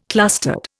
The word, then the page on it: clustered: Indian